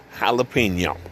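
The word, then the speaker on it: jalapeño: My adventures with the jalapeno pepper, and that is how it is pronounced, my friends, jalapeno.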